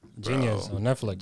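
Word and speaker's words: Jeen-Yuhs: Yeah, but the way it's spelled, JEENYUHS. – Yeah, it's supposed to be like genius. Yeah, like plural.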